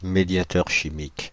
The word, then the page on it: médiateur chimique: /me.dja.tœʁ ʃi.mik/; France (Île-de-France)